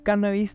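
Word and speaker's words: cánnabis: En cuanto a la acentuación, el término cannabis debe llevar tilde en la primera A, según Díaz Rojo, pues es una palabra esdrújula, ya que procede del latín cannabis, y en dicha lengua se pronuncia como drújulas todas las palabras que poseen la segunda sílaba breve, según Díaz Rojo.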